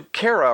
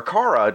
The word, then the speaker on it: Kara: And everyone knows who Kara or Kara, depending on your pronunciation, is now.